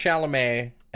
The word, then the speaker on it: Chalamet: It is pronounced Chalamet.